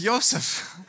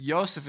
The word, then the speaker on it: Iosif: My name's Yosef. On the driver's license, it says Yosef, I-O-S-A-F, because that's my official name. And so I said to him, "I'm so sorry. My name is Yosef. It's spelled I-O-S-I-F.